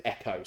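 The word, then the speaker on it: echo (ἔχω): And this Greek word echo, we would pronounce it echo, so it's, I think it's EKW or EWK, but it's echo.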